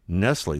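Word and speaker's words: Nestlé: As we reported on the show, because several environmental groups, not the Michigan Department of Environmental Quality, didn't want Nestle, that's N-E-S-T-L-E, but it's pronounced Nestle, wants to pump as much as 400 gallons a minute from underground water in western Michigan.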